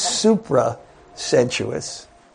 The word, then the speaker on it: supra-sensuous: And that experience that one has, it's supra, not super, because the next word is sensuous. It's supra-sensuous. And I really had to twist my Brooklyn accent to pronounce that properly.